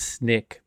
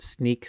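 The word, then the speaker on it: Snyk: I think Sneak, like I would read it as Snick, but Sneak seems like what they're going for with the weird spelling. – I think it is Sneak.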